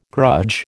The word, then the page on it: grudge: American English